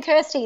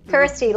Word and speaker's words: Kirsty: And thanks for saying Kirsty, not Christy. – Oh, yeah. – Americans never, always call me Christy. But yeah, Kirsty, like rhymes with thirsty.